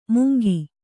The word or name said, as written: ಮಂಕ